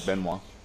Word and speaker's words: Benoit: Benoit.